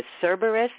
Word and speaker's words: Cerberus: and I may be mispronouncing this, Cerberus, is that the way you say? – That's it, yeah.